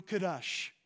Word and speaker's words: kadosh: This is pronounced in the Hebrew, kadosh.